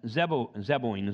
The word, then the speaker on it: Zeboim: Admah and Zoboam, of which the Lord overthrew in his anger and his wrath. Now, Admah and Zeboim is actually how you pronounce it.